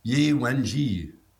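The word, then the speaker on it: Ye Wenjie: One of our main characters, I hate to say it, I'm looking up, Yi Wenji. – You pronounced it correctly, Wenji.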